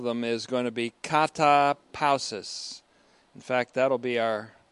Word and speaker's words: katapausis: Katapausis, or katapausis, the accent on the second syllable.